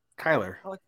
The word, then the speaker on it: Kyler: I think Kyler is it? Did you say Kyler or Kylar? – Kyler. – Kyler.